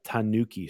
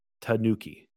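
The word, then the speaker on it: Tanooki: I believe it's pronounced this way. I've heard it pronounced a couple of ways, so I really hope I'm pronouncing it properly. It's the Tanuki suit. T-A-N-O-O-K-I-E or K-I. Tanuki.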